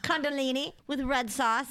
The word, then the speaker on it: Kundalini: So we are talking about, I'm going to say it wrong again, Kundalini awakening. Kundalini? – Kundalini. Sounds like a body part when you say it the other way.